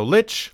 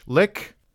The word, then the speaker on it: lich: So lich, lick, both of those are fine.